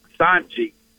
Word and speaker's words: Cijntje: Anyway, is it Girangelo Sinjay or Sinja? – Now, he says it's Sanji. – Like Sanji. – Girangelo Sanji. That's what I asked him Sanji. Specifically when out in Frisco. I said, I've heard 1,000 pronunciations. You tell me. And he said it's Sanji. Sanji. Like T-J-E. That's how you pronounce it.